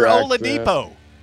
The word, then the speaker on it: Oladipo: That's how you say his name. It's Victor Oladipo.